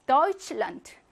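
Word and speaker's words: Deutschland: 대가 앞에서는 드, 맨 뒤에서는 트. 에오는 본모음 오이로 발음하고요. 테 에스체하는 취로 발음하고, 도이치와 란트, 독일의 나라라는 말이 합성 명사이기 때문에 강세는 앞에 오죠. 그래서 어떻게 발음합니까? 도이칠란트.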